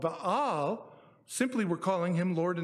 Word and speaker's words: Baal: There was a god worshipped that was called Baal. That's how you say it. Baal.